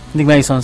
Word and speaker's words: Nick Mason: También déjame contarte que el año 2011 los ex Pink Floyd, Roy Waters, Devin Gilmour y Nick Mason. ¿Sí? ¿Te lo dije bien? – Nick Mason, sí. – Mason, sí, sí.